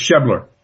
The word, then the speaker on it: Schebler: Sprecher. – It's Schebler.